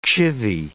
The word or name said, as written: chivvy